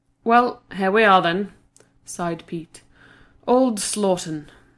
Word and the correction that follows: Slaughden: Joanne ducked her head back inside the car. "It's pronounced Slodden. I asked the woman at the hotel."